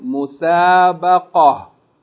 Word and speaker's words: مسابقة: If we stop on this word, we'll pronounce it مسابقة. Okay, the termabota will be pronounced as ha, and the vowel on it, or the tanween, would not be pronounced.